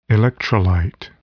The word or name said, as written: electrolyte